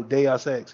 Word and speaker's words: Deus Ex: Yeah, she was the narrative director for that, and then she was the narrative director for um I think it's pronounced uh like Deus Ex or something. Oh, Deus Ex, yeah. Yeah.